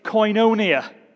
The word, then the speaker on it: koinonia: And it actually is based, this Greek word, which I'm not sure how to pronounce, okay? Apparently it's koinonia. Can you say that with me?